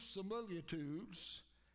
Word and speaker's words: similitude: I have also spoken by the prophets, and I have multiplied visions and used similitudes. Sometimes we just might not pronounce that quite right, but it's got that extra I in it, similitude, by the ministry of the prophets.